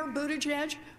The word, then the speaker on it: Buttigieg: Mayor Buttigieg really has a... – Oh, is that how it's pronounced? Okay. Finally. – Secret Dakota. – That's how it is. Buttigieg.